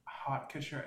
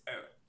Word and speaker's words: haute: It was more like, you know how people say haute couture? They say like hot couture. It's not how you say it. It's haute. – Yeah, because H-A-U-T in French is...